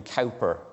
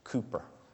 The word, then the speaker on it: Cowper: The poet William Cowper, which is wrongly pronounced, it's Cooper.